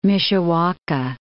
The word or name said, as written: Mishawaka